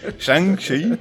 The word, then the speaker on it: Shang-Chi: Ou, como é, que, como é que fala mesmo o nome dele, Pedro? É, ele é Shang? Shang, né? Shang? – Shang. – Shang-Chi.